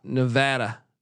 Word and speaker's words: Nevada: And even though you two were on the under, I really enjoyed the Nevada preview, but he says locals pronounce it Nevada.